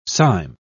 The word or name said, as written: cyme